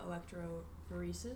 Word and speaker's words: electrophoresis: And as far as like using gel electrophoresis, is that how you pronounce it? – Yes, good job.